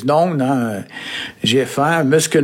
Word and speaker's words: naon: Et alors, ça s'écrit N-A-O-N. Alors, il y a des prononciations un peu variées selon les endroits. Hein. Ici, on dit noun, hein, très, très diphtongué.